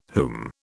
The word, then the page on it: whom: British English